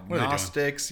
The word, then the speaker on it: Gnostics: Christians, well, on the other hand, they also annoyed me pretty bad by making a silent G. – Yeah. – On their. – Oh yeah, I know. Gnostics.